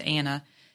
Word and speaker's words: Annah: It's Anna. My name is actually spelled A-N-N-A-H.